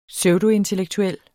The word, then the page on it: pseudointellektuel: ˈsœwdo-